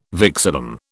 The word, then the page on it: vexillum: British English